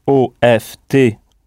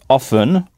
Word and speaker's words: often: Alors, vous connaissez peut-être déjà le mot often pour dire souvent, qui s'écrit OFTEN et qu'on prononce often ou often.